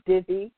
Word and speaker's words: Divi: Now, Divi is spelled D-I, V as in Victor, I, Divi.